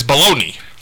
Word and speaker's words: Bologna: And if you ask me why, I'll say, because Oscar Mayer has a way with B-O-L-O-G-N-A. – Thank you, Superfan. You were commenting on the way he says baloney. – Yes, because he says Bologna. – Bologna. Well, that's how they say it at Ohio State.